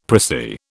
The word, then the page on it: prissy: British English